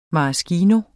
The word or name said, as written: maraschino